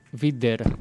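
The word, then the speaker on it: wieder: Το V είναι W παρεπτόντως. – Vida, Vider είναι. Με W γράφεται, ναι.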